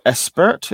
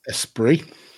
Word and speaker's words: Esprit: do you say Espert, is that? – Esprit. – But it's spelled Espert.